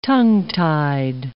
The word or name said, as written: tongue-tied